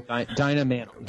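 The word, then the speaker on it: Dinah Manoff: The only person that was actually a teenager was Dina Minhoff. Is that how you say her name? – Dina Minhoff.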